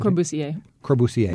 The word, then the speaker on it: Corbusier: Corbusier.